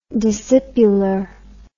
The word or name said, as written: discipular